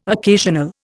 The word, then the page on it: occasional: Indian